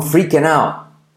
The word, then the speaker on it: freaking out: La R genera una U. Free. Free can. La I como E. Freaking out. La T de out casi no se dice.